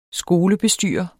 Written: skolebestyrer

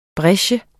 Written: brecher

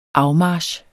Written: afmarch

